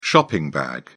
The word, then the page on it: shopping bag: British English